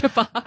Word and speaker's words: ciabatta: It's ciabatta. – Ciabatta bread. – Ciabatta bread, because that's how it's spelled. C-I-A-B-A-T-T-A.